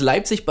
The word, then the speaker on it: Leipzig: Leipzig. – Thank you. – Leipzig, right? – Did I say it right? – You can actually. It's Leipzig, but oh shit, Leipzig.